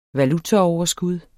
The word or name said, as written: valutaoverskud